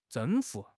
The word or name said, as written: 征服